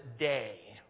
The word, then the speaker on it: Dei: The word is, and I spelled it out for you there in English, D-E-I. You'd pronounce it day.